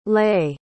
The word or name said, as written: lait